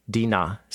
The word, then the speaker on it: Dinah: So now Abraham's grandson, Jacob, and he has a big family, and we're only told about one daughter born to him, Dinah, is how you say her name in Hebrew, or Dinah.